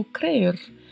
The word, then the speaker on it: creer: Esse R ao final é um R bem marcado, né? Ele é meio vibrato mesmo, tá? Crer. É importante treinar a língua para fazer a vibração deste R na pronúncia do verbo crer.